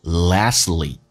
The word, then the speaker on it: lastly: Only you can feel the hope That's in you is so real and strong And lastly, and 的 D 去掉， last 这个单词英式发音叫 last，美式发音叫 last，而且 T 跟 L 在一起的时候经常 T 不发音，所以没有说成 lastly， it goes lastly，就好像 exactly 可以说成 exactly。